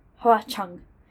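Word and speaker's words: Hua Cheng: Is that Hua Chang, yes. Chung. Hua Chung. But it's spelled H-U-A Cheng.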